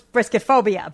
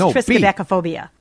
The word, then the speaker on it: triskaidekaphobia: I thought that was brisket phobia, but I think it was triskaidekaphobia.